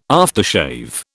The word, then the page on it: aftershave: British English